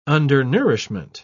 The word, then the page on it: undernourishment: British English